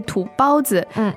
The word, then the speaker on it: 土包子: 土包子，其实这个词在生活中我们经常听到的是土包子这个发音。 – 对，那哪一个读音是正确的呢？ – 正确的读音应该是土包子。